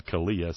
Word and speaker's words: CALEA: That's called CALEA, C-A-L-E-A. Hey, it rhymes with Scalia.